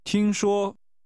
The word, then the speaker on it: 听说: 听说。 – Is like saying, hear it said. This is pronounced with two high-level tones.